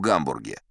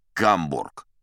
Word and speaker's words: Гамбург: Кстати, знаете, никак не пойму, почему это у нас твердо так произносится. Гамбург. Неправильно это. Тамошние жители называют свой город Гамбург. Оно и звучит помягче.